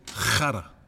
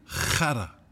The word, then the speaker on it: khara: Can you say khara? – Say it again. – Khara. – Khara. – Yeah, so K-H-A-R-A.